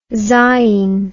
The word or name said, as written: zain